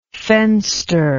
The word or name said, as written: fenster